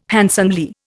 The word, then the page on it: handsomely: Indian